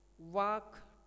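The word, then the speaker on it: walk: बारहवां केवल उच्चारित ध्वनियों के लिपि चिन्ह है, इसके विपरीत रोमन में उच्चारित ध्वनियों के भी लिपि चिन्ह है, यथा वाक टाक में ल का उच्चारण नहीं होता किंतु लेखन में एल का प्रयोग होता है।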